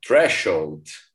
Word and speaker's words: threshold: A palavra threshold, falei certo? Threshold.